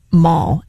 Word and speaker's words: maw: M-A-W. – During the break, I'm sounds like them, you're saying M-A, mall. M-A-L-L. – It's pronounced like mall, like shopping mall, but it's a term of endearment for one's grandmother, mall.